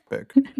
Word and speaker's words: Bach: Man spricht hier nicht Bach aus. – Beck. Bock. – Back. Diese amerikanische Schauspielerin.